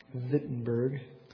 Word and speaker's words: Wittenberg: Wittenberg, get the pronunciation right.